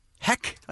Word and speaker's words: Heck: 106.7 K-Rock is K-R-O-Q. Congratulations to Felicia Heck. Heck, I believe is her name. Felicia Heck. – I mean, I can't see any possible other way to pronounce it. – Okay. – It's H-E-C-K.